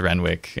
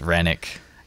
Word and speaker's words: Renwick: I only just found out that your last name is not pronounced Renwick and it's Rennick. – Yeah, that's my truth, and I'll sing it from the hills. It's a silent W.